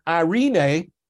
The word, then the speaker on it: eirene: Now, this word peace is the Greek word eirene, and it's spelled E-I-R-E-N-E.